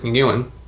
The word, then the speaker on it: Nguyen: Nguyen. Yes. Nguyen. – Yeah, I think it's Nguyen. Roughly it. – It's got a G in it, but the G is not pronounced.